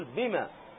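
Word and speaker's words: بمَ: تسأل مباشرة تقول بما، فتحة فقط، واضح أنها فتحة فقط، ما تقول بما، تقول بما فقط، فواضح أنك حذفت الألف فتكتبها على النطق.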